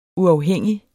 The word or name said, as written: uafhængig